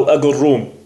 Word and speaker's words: آجروم: أو أجروم ليست مضبوطة في المصدر. وقال الرشيدي ثم جيم بربرية بين الجيم والقاف التي تنطق هكذا جا جا. قال وبعضهم يقول بالقاف وبعضهم بالجيم، وبها كان يكتب بخطه يعني المؤلف.